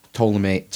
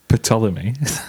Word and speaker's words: Ptolemy: Ptolemy. That dynasty of ancient Egypt. – Ptolemy? – Yeah, it's spelled like that. P-T-O-M. – The P is silent.